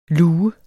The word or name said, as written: lue